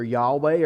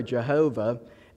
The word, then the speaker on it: Yahweh: which is pronounced either Yahweh or Jehovah.